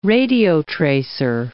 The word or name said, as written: radiotracer